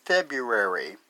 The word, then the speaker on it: February: I think nature understood humans would put up with winter for only so long and decided to invent February. And I'll spell that F-E-B-R-U-A-R-Y. Why? I always forget the first R in the month and mispronounce it.